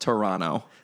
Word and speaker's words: Toronto: I love how he says Toronto. If any Canadians are listening right now, I apologize. It's pronounced Toronto.